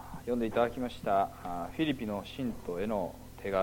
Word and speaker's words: フィリピ: 新教徒はフィリピの信徒への手紙、これはギリシャ語に原点のですね発音に近づけようということでフィリピという発音になっております。